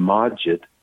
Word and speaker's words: Macit: So so he brought over this elderly Turkish guy named Majid, uh-huh. – which is spelled M-A-C-I-T. And his last name is Gural, G-U-R-O-L.